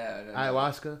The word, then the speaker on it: ayahuasca: Ayahuasca? – Oh, okay, you nailed it. I thought you said it wrong.